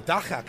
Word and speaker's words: dagga: I am here with the dagga couple. – You said it correctly. – South Africa. I studied. I'm a radio guy. I have to learn how to pronounce things. So dagga, of course.